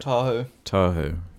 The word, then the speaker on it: Tahoe: How do you say it? – Tahoe.